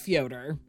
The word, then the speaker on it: Fyodor: As Pavlova somebody who took a year and a half of Russian in college, it's pronounced Fyodor.